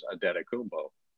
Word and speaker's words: Adetokunbo: Because apparently that's the way the American pronunciation is Adetokunbo.